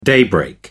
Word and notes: daybreak: American English

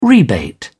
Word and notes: rebate: British English

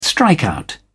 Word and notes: strikeout: British English

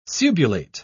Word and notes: subulate: British English

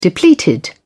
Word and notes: depleted: British English

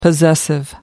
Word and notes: possessive: American English

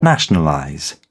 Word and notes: nationalize: British English

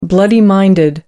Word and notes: bloody minded: American English

